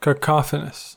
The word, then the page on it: cacophonous: American English